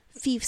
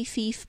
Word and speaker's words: Phoef: because it's spelled P-H-O-E-F, and I wasn't sure. I thought maybe Fief, but I wasn't sure, so I did look it up to see how it's pronounced, and it is the nickname of Robert Christopher Sutton, who is an Emmy Award-winning writer.